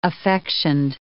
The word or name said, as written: affectioned